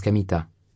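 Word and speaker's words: Kamita (神田): Il s'écrit comme Kanda, mais se prononce Kamita, répondit Kamita.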